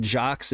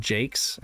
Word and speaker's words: Jacques: I learned that Richard Jacques is not Richard Jacques, it's Richard Jakes. I've been saying it wrong my whole life, or at least for a couple decades.